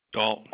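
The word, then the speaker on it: Gault: It is 3000 G-U-L-T. How do you say that, Galt? – Galt, yeah.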